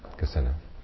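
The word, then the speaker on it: kasina: It's spelled K-I-S-I-N-A, Gesina.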